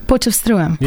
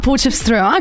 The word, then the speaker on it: Potchefstroom: Potchefstroom. – Yeah. of Potchefstroom. Porch. Okay. I had to. I had to figure out what you were saying. Now Potchefstroom.